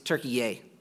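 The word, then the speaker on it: Türkiye: Most Americans call it Turkey, but apparently it's Turkey-ye. When we flew Turkish Airlines, I learned that, and I thought it was funny for some reason, Turkey-ye. I guess if my country was Turkey, I would pronounce it differently too, but Colossae was in Turkey, Turkey-ye, and was not a major city.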